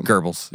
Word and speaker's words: Goebbels: Goebbels, yeah.